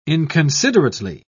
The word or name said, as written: inconsiderately